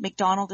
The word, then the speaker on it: McDonald: McDonald is spelled M-C-D-O-N-A-L-D, so I'm a Mick, not a Mac.